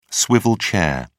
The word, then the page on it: swivel chair: British English